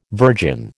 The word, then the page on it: virgin: American English